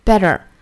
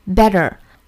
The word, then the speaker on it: better: 那这句话呢，美音版我们要注意 better，美音版读成 better， better。